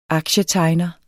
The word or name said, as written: aktietegner